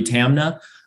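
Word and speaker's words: Taamneh: Tamna, I actually believe is pronounced,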